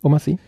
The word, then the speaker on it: Omar Sy: Mit Omar Sy in der Hauptrolle, spricht man die so aus? Weiß ich nicht. – Ja. Omar Sy.